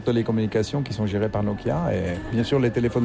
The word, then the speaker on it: Nokia: Mais il faut pas accentuer la prononciation de chaque lettre. – Non. – Nokia. Non. – On pourrait, mais c'est bon aussi Nokia.